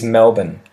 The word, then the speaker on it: Melbourne: And again, sort of like Canberra, this one has a spelling that often tricks people because it's spelt M E L B O U R N E. M E L B O U R N E. A lot of people, especially Americans, will often try and say that R really um, strongly at the end of the word. They will really They'll really try and pronounce that R. However, the average Australian would call the place Melbourne.